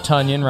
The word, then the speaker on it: Tonyan: Robert Tonyan rhymes with Funyon.